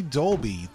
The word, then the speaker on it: Dolby: This day, 2013, Ray Dolby, the U.S. engineer who founded... – Also known as Dubley.